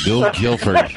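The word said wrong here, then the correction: Gil Billford: No, Gil Bilford.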